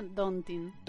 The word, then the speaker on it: daunting: Mi sponsor no autorizado del chiqui mensaje me dice que Doubting se pronuncia daunting.